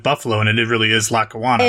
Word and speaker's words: Lackawanna: Also, I gotta say, because this is amazingly kind of like specialty subject for me, it's not Lackawanna Blues, as you pronounce. It's Lackawanna. It's a suburb of Buffalo. I know Lackawanna very well. Lackawanna, New York.